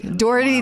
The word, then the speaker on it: Daugherity: Yes, I tell my students it rhymes with authority. Doherty the authority.